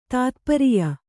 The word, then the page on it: ತಾತ್ಪರಿಯ: tātpariya